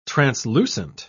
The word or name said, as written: translucent